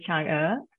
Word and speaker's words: Chang'e: I pronounce it as Chang'e. – Chang'e, okay.